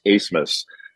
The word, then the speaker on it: Abmas: You remember Oral Roberts beat Ohio State a couple years ago, and they still have the same guard, Max Asmus, spelled A-B-M-A-S, and it's pronounced Asmus.